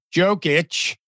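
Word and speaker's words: Jokić: His name is, I mean, if you pronounce the J like a J, he's Djokic. And then the other one is Djokovic.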